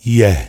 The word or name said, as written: Ye